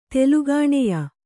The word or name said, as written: ತೆಲುಗಾಣೆಯ